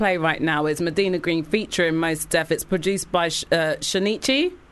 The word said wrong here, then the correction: Shaughnessy: Shaughnessy Ice Cold. – Shaughnessy. You had to verify the hip-hop pronunciation.